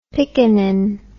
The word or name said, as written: piccanin